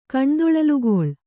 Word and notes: ಕಣ್ದೊಳಲುಗೊಳ್: kannada

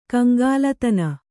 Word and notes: ಕಂಗಾಲುತನ: kaŋgālutana

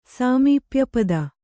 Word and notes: ಸಾಮೀಪ್ಯಪದ: Kannada

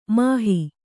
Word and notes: ಮೋಹ: Kannada

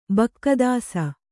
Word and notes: ಬಕ್ಕದಾಸ: bakka dāsa

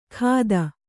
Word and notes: ಖಾದ: khāda

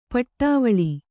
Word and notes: ಪಡವಳಿ: paḍavaḷi

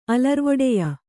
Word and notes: ಅಲರ್ವೊಡೆಯ: kannada

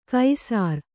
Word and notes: ಕೈಸರ: kaisara

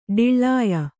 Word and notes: ಢಿಳ್ಳಾಯ: ḍhiḷḷāya

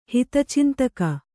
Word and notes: ಹಿತಚಿಂತಕ: hita cintaka

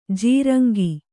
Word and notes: ಜೀರಂಗಿ: jīraŋgi

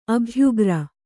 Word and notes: ಅಭ್ಯುಗ್ರ: abhyugra

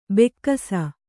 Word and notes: ಭಾಗಿಸು: bhāgisu

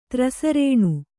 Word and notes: ತ್ರಸರೇಣು: trasarēṇu

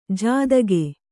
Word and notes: ಜಾದಗೆ: jādage

